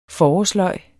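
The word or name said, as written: FORÅRSLØGET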